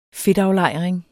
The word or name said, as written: fedtaflejring